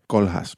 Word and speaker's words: Koolhaas: de Rem Koolhaas, pronunciándolo como dirían los holandeses.